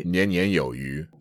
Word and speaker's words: 年年有余: Yeah, it's a slang called 年年有余. – 年年有余. – Yeah. So the 余 here means sufficient, but it has the same pronunciation as fish.